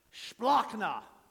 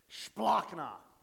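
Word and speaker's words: splanchna: Splachna. Sounds like something Worf would say on Star Trek. Klingon, but it's not. Klingon, no, it's Greek. Splachna. S-P-L-A-N-C-H-A. That's how you say it.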